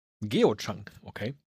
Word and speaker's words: Geochang: G-E-O-C-H-A-N-G. – Geochang, okay. So wie Michael Chang, aber als ja.